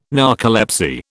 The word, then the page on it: narcolepsy: British English